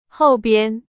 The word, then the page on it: 后边: Chinese